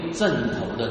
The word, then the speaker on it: 枕: 不过我们刚才有念有一个发音没有念准的。 58节啊，只是人子没有枕头的地方，所以不是枕头。